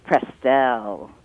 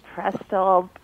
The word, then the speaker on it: Prestell: It's Prestell. – Ah, see, that's why I asked. Prestell Askia? – And thank you for asking. Prestell, actually, it's Prestell. It's a combo, dad and mom. Preston and Adele.